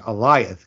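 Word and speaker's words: Alioth: Eliath, sorry, I couldn't remember the pronunciation. Eliath, because it's actually A-L-I-O.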